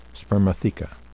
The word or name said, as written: spermatheca